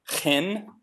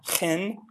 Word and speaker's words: chen: And in the Old Testament, grace is a word pronounced chen. It's got a nice little ch sound there, chen.